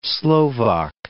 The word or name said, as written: Slovak